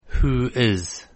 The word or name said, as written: who is